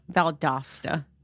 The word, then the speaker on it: Valdosta: And this took place in Valdosta, is how you say it. Valdosta, Georgia? Or Valdosta? – Valdosta.